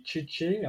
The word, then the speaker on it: CC: Je sais pas s'ils font ça tous les soirs, mais donc il y a Jean-Luc et Tchétché, hein, c'est CC, ça s'écrit CC, mais ça se prononce Tchétché.